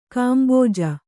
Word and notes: ಗುಂಬಜ್: gumbaj

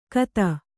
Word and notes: ಖತ: Kannada

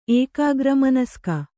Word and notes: ಏಕಾಗ್ರಮನಸ್ಕ: kannada

